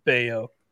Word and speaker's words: Bello: Brian Bayo, versus B E L L O.